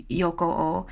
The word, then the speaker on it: Yokoo: And so then you say his last name, Yokoo.